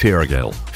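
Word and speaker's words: Feargal: I think you'll find it's pronounced Fergal. Fergal.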